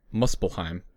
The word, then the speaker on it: Muspelheim: I just got to Muspelheim, by the way. – There you go. Somebody knows how to pronounce it.